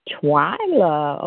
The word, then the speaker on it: Twylia: It's pronounced Twyla. – Twyla. Okay. – Twyla. Yes. – I just wanted to make sure I got that right. – Okay. Yeah, the I is silent.